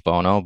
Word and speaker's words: Bohno: But I think phonetically it makes more sense on my DJ name since that H is in the middle there. – Yeah. – Pronounced Bohno, but yeah, it's either way because it's a family nickname.